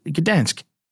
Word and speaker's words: Gdansk: I had to go and look up how to pronounce this, and yeah, it is just Gdansk. Gdansk in Poland had a regional from November 25th to 26th and had 576 players, if you can believe it.